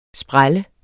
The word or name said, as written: spræl